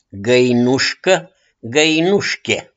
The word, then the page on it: GĂINUȘCĂ: GĂINUȘCHIE